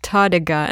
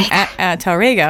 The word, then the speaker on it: Tárrega: As if there's an Tárrega, as if there's an accent on the E, but there's no accent, it's on the A, so it's Tárrega.